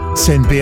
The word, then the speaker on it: CNP: Senpi Assicura ti offre soluzioni di risparmio, investimento, protezione e la solidità di un gruppo con 170 anni di storia. Si legge SNP, si scrive CNP.